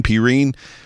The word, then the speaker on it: Perine: LaMichael Perine. That's what they kept saying. So I was trying to catch his nameplate on the back of his shirt through the whole game, because that's just Perine. It's just a weird way to say his name. So I was expecting like the letter P in a dash and then Ryan or Reen, but sorry, I digress.